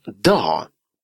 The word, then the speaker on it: dag: «Ännu en dag». «Ännu» – «еще». «Ännu en dag». «День». «En dag». Обратите внимание, что буква «G» в конце этого слова не читается.